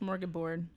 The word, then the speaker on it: smorgasbord: Yes, so a plethora, a smorgasmorg. – Stop saying that word. You can't say it right. – Smorgasbord.